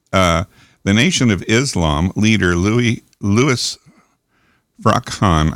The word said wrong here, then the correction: Farrakhan: I can, I can never say his name right, frakhan Farrakhan, Farrakhan.